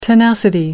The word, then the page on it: tenacity: American English